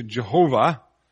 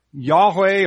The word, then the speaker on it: Yahweh: Some Bibles turn it into Jehovah. It's actually a mistake. It's a little mix-up in the Hebrew. Yahweh or Jahweh would be the closest pronunciation.